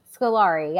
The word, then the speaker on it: Scolari: We need to learn how to say these last names. – Scolari, yeah.